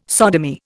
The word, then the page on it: sodomy: Indian